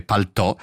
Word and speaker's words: paltò: L'origine la si trova nel francese paletò, in italiano con l'accento grave paltò, sì.